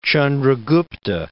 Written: Chandragupta

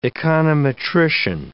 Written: econometrician

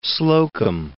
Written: Slocum

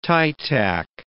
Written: tie tack

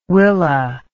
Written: Willa

